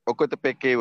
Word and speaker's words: Ocotepeque: One was, am I correct to pronounce that word called Ocotepec? Is it correct? – All right. Ocotepeque was the first place I was in.